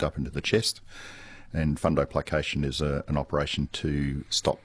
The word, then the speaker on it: fundoplication: He's asking about fundoplication.